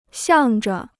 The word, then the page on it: 向着: xiàng zhe